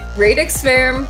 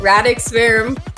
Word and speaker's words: Radix Verum: No, it's Latin, so it can go either way. It can be Radix Verum, Radix Verum. I don't mind. I actually don't even know like what the correct, like proper Latin pronunciation of it is, but I've always just said Radix.